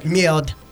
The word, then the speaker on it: merde: Because we are in the United States and not speaking French on the radio, we can say merde, is what merde. – We say. Merde. – You have to say that correctly. Merde.